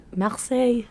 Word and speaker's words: Marseille: Marseille.